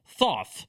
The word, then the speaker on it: Thoth: Tahota, Thoth, for those that don't know how the pronunciation is supposed to actually be.